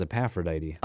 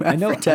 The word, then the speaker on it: Aphrodite: Who in the world is Epaphrodite? – I don't know. I think you're just adding an extra syllable to the name Aphrodite.